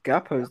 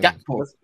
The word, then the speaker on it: Gakpo: Gakpo. – Yeah, what's come on, you can't just be skipping out a random, like, it's a K. There's no such thing as a silent K.